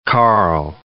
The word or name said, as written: Carl